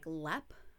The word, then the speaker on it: Leap: So the castle's name is pronounced like Lep, but it's spelt like the word leap.